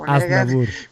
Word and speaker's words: Aznavour: A parte la pronuncia del cognome, diciamo che quella è la canzone. – Perché come si legge? – Aznavour.